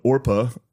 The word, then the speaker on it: Orpah: I feel like Oprah's Her first name was spelled Orpah, O-R-P-A-H, on her birth certificate. But people mispronounced it regularly, and so Oprah stuck.